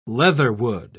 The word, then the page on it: leatherwood: British English